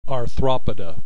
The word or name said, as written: Arthropoda